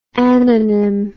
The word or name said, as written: ananym